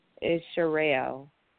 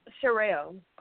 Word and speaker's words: Sherelle: It's Sherelle. – Oh, Sherelle. Sherelle. You said yes. – it with a C or a S? – A S. Sherelle.